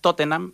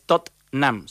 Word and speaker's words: Tottenham: Y siempre dice, no es Tottenham, es Tottenham, solamente con dos consonantes.